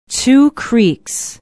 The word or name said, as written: two creeks